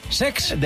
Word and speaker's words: Cesc: No lo he dicho mal, es Sex.